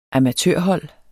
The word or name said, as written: amatørhold